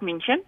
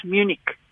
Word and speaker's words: München: Dis die Duitse uitspraak München. Ehm um, en en dit is ook die Afrikaanse spelwyse en die Afrikaanse uitspraak. En as daar as as iemand daardie naam uitspreek as Afrikaans sprekende persoon in Afrikaanse konteks daardie naam uitspreek as Munich dan is daar altyd iemand uh, wat gaan beswaar maak hm.